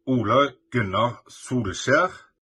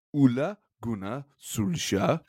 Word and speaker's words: Ole Gunnar Solskjær: Ole Gunnar Solskjær. – Ole Gunnar Solskjær. – Ole Gunnar Solskjær has been sacked and is pissed off.